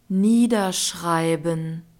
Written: niederschreiben

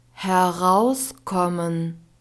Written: herauskommen

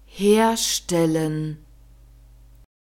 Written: herstellen

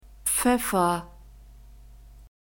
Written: pfeffer

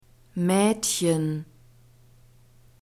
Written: maedchen

